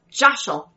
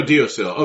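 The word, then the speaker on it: deosil: Sometimes pronounced Joshel. – Joshel? – Yeah, because it's Gaelic. – Yeah. – Okay. – Sometimes it's pronounced like Jishel, Jossel, Jossel, Jishel.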